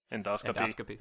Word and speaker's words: Endoscopy: Endoscopy.